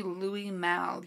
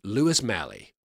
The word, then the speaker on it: Louis Malle: Oh, it's actually Louis Malle. You're mispronouncing it. – Are you sure? Oh, because look at this. That reads, if you look there, That reads Lewis Malley to me.